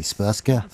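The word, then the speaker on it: Srpska: Like, there is the Serbian majority republic, I'm going to mispronounce this horribly, Spurska. – Yeah, so not far off anyway.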